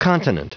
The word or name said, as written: continent